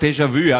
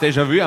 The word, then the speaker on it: Déjà-vu: Alter, Harvey sagt Déjà-vu, Harvey. Das heißt Déjà-vu einfach und das ist für die was völlig normal, dass ich das so renne.